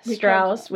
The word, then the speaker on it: Strauss: Richard Strauss, we have learned is the pronunciation.